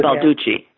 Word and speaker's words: Baldacci: And I've been reading a lot more, and I've just discovered, not that he's wonderful, I like reading David Baldacci or how he pronounced it. Because Balducci.